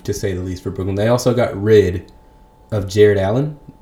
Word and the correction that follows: Jarrett Allen: I, and I don't think I pronounced the T on that name hard enough. It's Jarrett Allen, pardon me, and a solid center, the Fro.